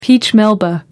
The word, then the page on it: peach melba: American English